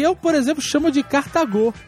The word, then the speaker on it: Cartago: Durante todo o Nerdcast, os integrantes pronunciaram o nome da cidade de Aníbal como se fosse uma proparoxítona. – Olha aí, sabia. Não era, viu? – Cartago. No entanto, se a ênfase estivesse na primeira sílaba, como vocês falaram, a palavra seria acentuada. O nome da cidade é Cartago.